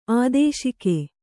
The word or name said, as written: ಆದೇಶಿಕೆ